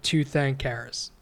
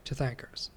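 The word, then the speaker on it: Tirthankara: Yeah, how do you say that? Tirthankaras. – Yeah, like Tirthankaras. – Right. – Tirthankaras.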